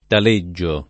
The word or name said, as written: Taleggio